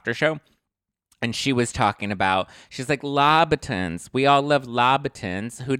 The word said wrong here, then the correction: Louboutin: She means Louboutins, as in Christian Louboutin, the red bottom shoes. They're pronounced Louboutin. Louboutin. I think it's Louboutin.